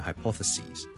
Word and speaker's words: hypotheses: So, hypothesis, hypothesis, plural, 我们是 S-I-S 转成 S-E-S, 就变成 hypothesis, hypothesis, hypothesis, 记住个发音 ,ok? 由 sis 转成 sees, 你当好像是 C-S-E-A 或 S-E-E, 嗰个 C 跟住再加多个 S， so singular hypothesis， plural hypotheses， singular crisis， plural crises。